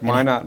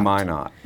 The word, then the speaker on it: Minot: Minot, North Dakota. – Well, that was my second question, is how you pronounce that. Minot. – Minot, yes.